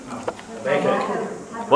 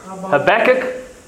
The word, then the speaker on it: Habakkuk: Habakkuk. – What's it? Habakkuk? Is that one? Habakkuk is another, or Habakkuk is another. All right, they are all acceptable English ways of pronouncing the word.